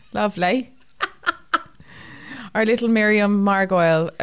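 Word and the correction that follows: Margolyes: Margulies, sorry.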